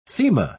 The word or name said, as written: thema